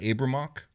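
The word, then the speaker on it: Abriymoch: Would you pronounce that Abramok? – Yeah, I kind of keep that Y mostly silent there.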